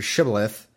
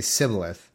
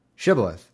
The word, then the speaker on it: Shibboleth: And they said, well, say this word, which is Shibboleth. It's probably pronounced some stupid Shibboleth or something weird. And they would say it incorrectly. They would say Sibboleth.